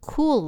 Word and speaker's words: Kuel: Well, she just got pissed off enough to do something about it, and that something has since blossomed into a platform called Cool Life, that's spelled K-U-E-L, Life, which delivers relevant, ad-free content to women 45 and up.